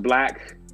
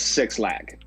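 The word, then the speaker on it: 6LACK: And then I would say my low key one of my low key favorite artists is technically his name is pronounced Black, but everyone knows him as Six Lack. So because he spells it the number 6 LACK.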